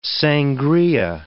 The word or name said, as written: sangria